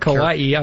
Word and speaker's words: kawaii: K-A-W. How do you say the Japanese? – Yeah, like, kawaii. I was just trying to figure out if that's kawaii. Kawaii.